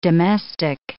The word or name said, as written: domestic